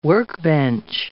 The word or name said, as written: workbench